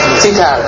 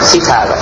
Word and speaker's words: sitar: Sitar, I'm sorry for saying it wrong. Sitar.